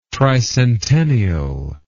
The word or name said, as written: tricentennial